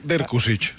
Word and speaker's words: Dirkusic: Em sembla que s'ha de pronunciar Dirkusic. – Ah, exacte, Dirkusic. – Dirkusic. Sí.